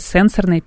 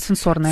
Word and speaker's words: сенсорная: Сенсорная или сенсорная? Тут вот я пыталась разобраться. У нас просто есть слушатели, которые очень бдят за тем, как ставится ударение, как произносятся слова. И выяснилось, что она сенсорная. – С психологической точки зрения, да, она сенсорная. Сенсорная